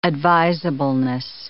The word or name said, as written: advisableness